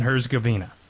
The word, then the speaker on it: Herzegovina: We're on to Bosnia and Herzegovina.